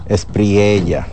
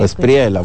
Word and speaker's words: Espriella: Incluso muchos presidentes han felicitado, incluyendo al presidente dominicano Luis Abinader, al virtual ya ganador de las elecciones en Colombia, que es se me dificulta siempre pronunciar ese apellido. – Espriella. – O Espriella también. – Espriella, puede ser en italiano también.